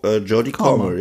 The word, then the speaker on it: Jodie Comer: Habe ich ihren Namen richtig gesagt? Ich guck gerade. – Jodie Comer, ja.